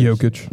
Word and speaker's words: Jokic: Jokic.